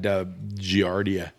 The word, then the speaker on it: giardia: I've had giardia, and is that how you pronounce it? – Giardia lamblia.